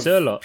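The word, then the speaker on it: Sørloth: He looks like a Sorloth. Pure Viking. Yeah, Sorloth? Sorloth? – I think it's pronounced Sorlot. Fuck, I'm going to get this wrong now. That's like the Norwegian way of saying it.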